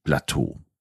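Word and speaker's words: platå: Also P-L-A-T, A mit Kringel und dann der Berg. Und so wird aus dem Französischen Plateau eben das schwedische Plateau. Genau gleich ausgesprochen, aber eben sehr schwedisch geschrieben.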